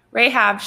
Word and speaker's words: Rahab: Rahab? – Rahab, sure.